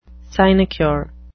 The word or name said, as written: sinecure